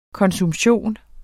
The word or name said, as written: konsumption